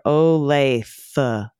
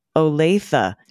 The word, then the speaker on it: Olathe: You mispronounced Olathe, Kansas, just like your Cleveland CEO. The pronunciation isn't Olaf. Like is not Olaf like laugh. It's rather Olathe. Olathe.